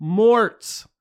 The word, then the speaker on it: Mort: Usually I get Morris, which is pretty funny. – Morris? – Yeah, yeah. Because I overpronounce Mort a lot of times trying to get them to understand what I'm saying. So I'll go like, Mort.